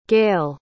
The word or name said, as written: Gael